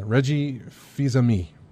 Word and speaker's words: Reggie Fils-Aimé: Yeah, Reggie Fils-Aimé, I believe, is how it's pronounced.